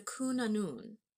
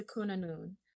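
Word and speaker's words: Cŵn Annwn: The 3rd one is the Cunanun, which is spelled C-W with a chevron diacritic on top, N, space, A-N-N-W-N. I looked up the pronunciation of that because it's just so many consonants.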